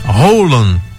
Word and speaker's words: Haaland: Segundo dizem, em norueguês, a pronúncia do atacante Haaland, que é escrito H2As+1LAND, segundo dizem, a pronúncia é Haaland. – É. – Roland, né? – Isso.